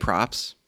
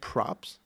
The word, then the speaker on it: Proops: Greg Props? – Yep. It's P-R-O-O-P-S, so it's Proops. But I'm pretty sure you pronounce it props.